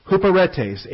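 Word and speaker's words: huperetes (ὑπηρέτης): In addition to that, we also have the second term, huperetes, H-U-P-E-R-E-T-E-S. H-U-P-E-R, that's the long E, eta. So, upsilon, P, eta, rho, epsilon, tau, eta, sigma. Long E, short E, long E.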